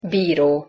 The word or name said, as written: biro